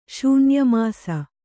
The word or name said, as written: ಶೂನ್ಯಮಾಸ